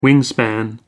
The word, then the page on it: wingspan: American English